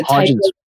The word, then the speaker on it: Hodgkin's: It's a Hodgkin's.